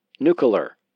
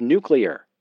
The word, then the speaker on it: nuclear: Partly out of laziness and probably lousy education, I often mispronounced nuclear. I'd say nuclear. I'd get mail from viewers correcting me that it was nuclear.